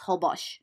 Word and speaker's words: Holbox: It's spelled Holbox, but it's pronounced Holbosch.